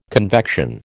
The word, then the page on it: convection: American English